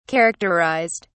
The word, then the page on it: characterized: British English